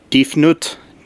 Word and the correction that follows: Tefnut: I think it was Tefnut. – Tefnut, it begins with a D, but maybe it's pronounced with a T.